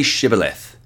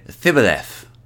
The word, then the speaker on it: Shibboleth: When he said, "No," they said to him, "Then say Shibboleth." And he said, "Thibboleth," for he could not pronounce it right.